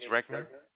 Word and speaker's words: Regner: My name is Regner. – Can you spell that for me? – R-E-G-N-E-R.